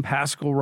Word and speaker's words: Paschal: And I think a huge part of his success is his name, Paschal, rhymes with rascal.